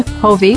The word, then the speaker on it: Hofve: Our special guests today are Dr. Jean Hovey, which is hard to pronounce, but it's spelled H-O-F-V-E, correct? – Yep, rhymes with Bon Jovi.